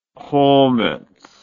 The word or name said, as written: chametz